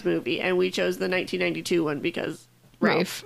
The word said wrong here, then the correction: Ralph: Rafe. Gosh. Guys, it's spelled Ralph.